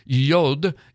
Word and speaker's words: Yod: Yod means hand, but Yod is how you pronounce that first letter, Yod.